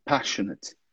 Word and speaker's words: passionate: A gente diz passionate. Você falou lá atrás, passionate. So, passionate. Passionate.